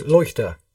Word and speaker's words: Leuchter: How do you pronounce it? Is it Leuchter, is it? – Fred Leuchter, I think. Leuchter. – Leuchter, sorry.